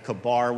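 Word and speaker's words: Chebar: It depends how you pronounce that. Kabar would be more like the Hebrew. It's usually anglicized as Kibar.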